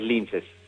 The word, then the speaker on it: Linces: Buenas tardes y decime si estoy pronunciando bien el nombre de la banda. – Hola Tapa, ¿cómo estás? Estoy bien. Sí, es Linces, está perfecto.